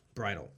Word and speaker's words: Bridahl: B-R-I-D-A-H-L. And I'm pronouncing it Pete Bridle.